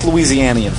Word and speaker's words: Louisianian: And now we've got true South Louisianian food. Did I say that right? – You did.